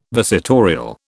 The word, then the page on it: visitorial: British English